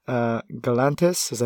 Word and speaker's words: Galantis: Michael Bublé, Romeo Santos, Galantis.